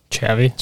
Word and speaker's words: Xavi: Xavi or Xavi? – Xavi. – Xavi? Xavi Simmons? – Yeah, Xavi Simmons.